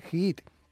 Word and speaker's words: HIIT: Se escribe H-I-I-T, Hit.